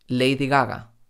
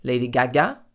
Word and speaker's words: Lady Gaga: Gaga, Lady Gaga. En español no decimos Lady Gaga, sino Lady Gaga.